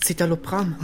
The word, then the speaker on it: Citalopram: Das ist Citalopram.